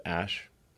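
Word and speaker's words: Æ: X, the letter X, and then the A-E is like pronounced Ash.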